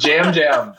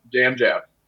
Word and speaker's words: Yam Yam: It's Jam Jam. He put out a video saying it's pronounced Jam Jam. – But on the screen, they spell it Yam Yam. Like it's a meat. – But you just Jam Jam. You pronounce the Y as a J.